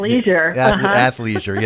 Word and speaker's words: athleisure: From that standpoint, athleisure. – Yeah, that's it. The ick is out of there. Just take the ick right out of that, and then make things.